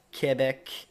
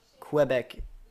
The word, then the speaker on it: Quebec: Or Quebec. I always pronounce Quebec in English.